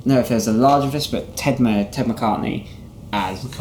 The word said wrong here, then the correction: McCarty: McCarty, I can never say his name.